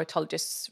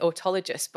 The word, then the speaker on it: autologous: As I said, we're going to be talking about own tissue or autologous reconstruction. Now, I always pronounce it autologous, but I've noticed I think I'm saying it wrong and it's an autologous reconstruction.